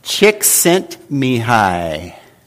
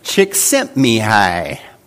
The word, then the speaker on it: Csikszentmihalyi: Csikszentmihalyi. Yeah, it's right there on the board. I tried to spell it out for you. Mihai Csikszentmihalyi.